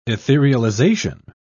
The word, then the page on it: etherealization: British English